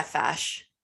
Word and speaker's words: Fache: Fache? Yeah, Fache.